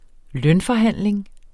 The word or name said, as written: lønforhandling